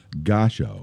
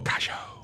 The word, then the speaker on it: Gashco: It is spelled G-A-S-H-C-O, pronounced Gosho. – Gosho.